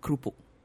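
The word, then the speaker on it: kroepoek: But the Dutch pronunciation would be two syllables, krupuk.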